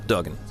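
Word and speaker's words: Duggan: Did I say his name right? Dugan, all right.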